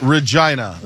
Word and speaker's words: Regina: It's Regina. That's the way it's pronounced.